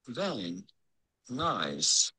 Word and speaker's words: Van Nuys: How do you go about pronouncing it? Van. Nice.